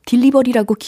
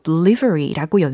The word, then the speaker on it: delivery: 딜리버리라고 기억해두면 안 들리는 단어였던 거죠. 정확한 발음으로 delivery라고 연습을 해두니까 들리는 겁니다.